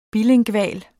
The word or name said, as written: BILINGVAL